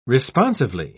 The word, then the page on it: responsively: British English